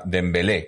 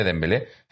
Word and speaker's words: Dembélé: Los que quedamos por aquí, más todos los que estáis por aquí todavía, Dembélé, con el acento en la E, ¿no? Dembélé, Dembélé, eh, con el acento en la E.